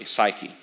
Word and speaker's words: psyche: Usually it's a U in the middle, but it's pronounced very similarly, psyche.